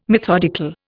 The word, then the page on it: methodical: Indian